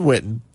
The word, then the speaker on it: Witten: We heard earlier this week that Jason Witten, or as Stephen A. Smith likes to call him, Jason Witten, there's T's in the name. Pronounce the T's.